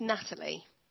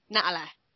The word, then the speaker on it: Natalie: My real name's Natalie. – Okay. – But because of where I live and the accent in our area, the T's don't get pronounced, so I'd be Natalie or Natalie.